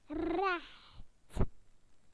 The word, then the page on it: straight: British English